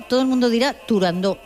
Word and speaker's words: Turandot: pues no había que decir Turandot, sino que, a pesar de que tuviera una T...